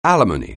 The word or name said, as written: alimony